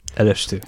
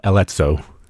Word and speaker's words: Élesztő: It's a Élesztő. Élesztő. And Élesztő, yeah. And if you look at it, it looks like uh, Eletzo and if you say Alezzo, people are probably going to stare at you funnily.